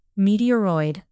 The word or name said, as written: meteoroid